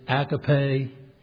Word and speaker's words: agape: And then the fourth kind is agape. Some pronounce it agape.